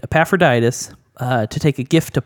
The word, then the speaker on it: Epaphroditus: I just want to congratulate you on pronouncing Epaphroditus so like clearly.